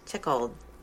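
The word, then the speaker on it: Tschichold: Jan Tschickold.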